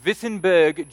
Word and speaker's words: Wittenberg: Wittenberg, Germany, they pronounce the W's like V's, and it just sounds cooler when you say it that way, he denounced the practice of indulgences with 95 statements against this practice.